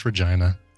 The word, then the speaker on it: Regina: It is pronounced the Regina. Yeah. Saskatchewan. Yeah, I don't want to live in Regina. I want to live somewhere that doesn't rhyme with vagina.